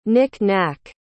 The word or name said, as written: knick-knack